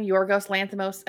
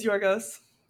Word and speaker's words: Yorgos Lanthimos: This is, what's his name, Yorgos Lanthimos? How do you say his name? – Just like you did. – Wow, amazing. – Nailed it, first try. – Yeah, thank you. – It is Yorgos.